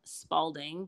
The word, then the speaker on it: Spaulding: So you can find me at C Spaulding, but my last name is spelled S-P-A-U-L-D-I-N-G 2 on Instagram.